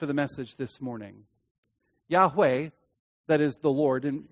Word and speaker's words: Yahweh: That is the memorial name of the Lord, which most commonly is pronounced Yahweh.